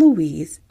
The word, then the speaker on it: Louis: Jenny allowed them to stay up later than usual to play with their new gifts, but she reminded the boys, Morris and Louise, oh sorry, it's not Louise, it's Louis. It's because I call one of my cousins Louise and I spell it like that. It's Louis, sorry.